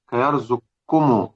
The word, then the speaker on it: يرزقكم: كيرزقكم، طبعا يرزقكم هنا لها أكثر من نطق، والأفضل أن نقول كيرزقكم واثقكم وخلقكم، تمام كده، وميثاقكم أظهر ونرزق كنجلة.